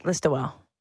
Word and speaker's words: Listowel: Listowel.